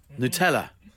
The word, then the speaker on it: Nutella: Nutella. – One more time. – Nutella. – Sorry, excuse me. – What's the problem? – Where's the ooh coming from? – That's how it's pronounced.